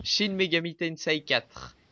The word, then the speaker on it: Shin Megami Tensei: Alors euh, ensuite, une autre localisation cette fois, mais celle de Shin Megami Tensei 4. J'espère que j'ai bien prononcé. Shin Megami Tensei. – Shin Megami Tensei 4.